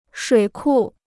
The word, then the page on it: 水库: shuǐ kù